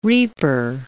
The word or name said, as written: reaper